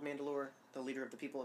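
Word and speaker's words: Mand'alor: These principles are primarily held and led by a single leader of the people known as Mandalore, and that's spelled M-A-N-D apostrophe A-L-O-R. So it sounds exactly like the planet name, but it's mm-hmm. spelled a little different.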